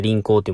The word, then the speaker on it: 輪行: えー、っと、また話変わるんですけども、えー、皆さん、輪行って知ってますえー、輪っかの輪に行くっていう字を書いて輪行って読むんですけど、